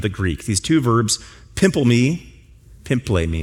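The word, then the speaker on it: pimple me (πίμπλημι): And one of those verbs, I wish I would have spelled this for you up here, is pimplemi, pimple, and then mi, if you want to spell it. Pimple my. That's how you'd spell it. That's not how it's pronounced.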